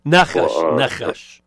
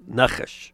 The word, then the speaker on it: nachash: Nakash. Yeah, it's a segulate word, so the syllable, the accent's on the first syllable, nachash.